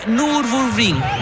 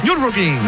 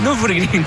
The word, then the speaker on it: Nürburgring: Corrió la Copa 2, como decía, la Cup 2, como se dice, y ganó las 24 horas de Nürburgring. Lo pronuncié muy mal. – Nürburgring. – Nürburgring, claro.